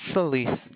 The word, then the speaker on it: Solis: It's Solis. – How do you spell it? – Solis. – Oh, just like it sounds, Solis.